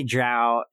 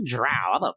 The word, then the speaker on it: drow: It's drow elf. It's got a D-R-O-W, right? – Yeah. – Yeah, that's drow. – It's drow.